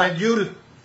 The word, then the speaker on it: Medjool: Medjool, ¿cómo se pronunciará? – Medjool.